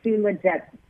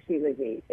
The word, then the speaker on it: shilajit: And so I pronounce it shilajit. Is that how you pronounce it? – Got it. – Okay, and we say like shilajit, Josh, you know that poem, shilajit. So that's how we say shilajit.